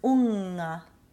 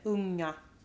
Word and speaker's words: unga: And will it, like in a word we had before, the word unga. So there's no g sound. This wouldn't be like we say in English finger. – No. No. You don't pronounce the G. You say unga.